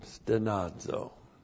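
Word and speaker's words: stenazo (στενάζω): Even we ourselves, and then the verb stenazo. S-T-E-N-A-Z, long O, stenazo.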